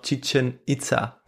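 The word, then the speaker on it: Chichén Itzá: Ich nehme noch Chichen Itza. Sehr schön. Das richtig ausgesprochen? Das hast du ziemlich gut ausgesprochen.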